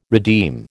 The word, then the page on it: redeem: American English